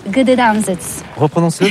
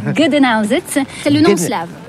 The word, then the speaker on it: Gdańsk: c'est Gdańsk. – Reprononce-le parce que... – Gdańsk, c'est le nom slave. Gdańsk. – Gedenanzit, que nous, Français, prononçons en disant Danzig.